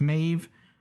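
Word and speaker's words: Maebh: Now, it's spelled Maeve with a B, but it's apparently pronounced Maeve with a V. It's kind of hard to tell in the episode itself, though. It's spelled M-A-E-B-H, B as in boy, but evidently it's pronounced V as in Vincent.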